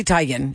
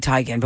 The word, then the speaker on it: Teigen: I didn't see this recent picture, but you know, Chrissy Teigen. It's actually Teigen, but we say Teigen and she just lets us say it like that.